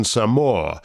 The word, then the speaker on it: Samoa: You'll notice the first song uses the old tourist pronunciation of Samoa, and the second uses the preferred native pronunciation, Samoa.